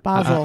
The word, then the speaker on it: Basel: Uh, this is an investigator initiated study supported by the Department of Medicine at the University Hospital uh, in Basel or Basel. – It's Basel. Uh, Basel.